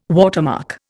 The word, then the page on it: watermark: Indian